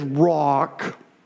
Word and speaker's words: Rock: My name is Rock.